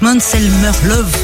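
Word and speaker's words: Måns Zelmerlöw: mixe l'Europe et pop rock avec Manselmer Love. Ça, on le prononce en suédois. Love à la fin, comme love, en fait.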